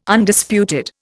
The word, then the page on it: Undisputed: Indian